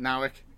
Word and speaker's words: Nauck: Nowick. – Sure. – I think he's a Canadian guy. – N-A-U, it's N-A... And I can see it in my head, N-A-U-E-K or something. Or N-A-U-C-K, yeah, Nowick, I'm sure.